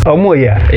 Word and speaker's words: Omoya: Omoya.